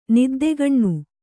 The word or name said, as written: ನಿದ್ದೆಕಣ್ಣು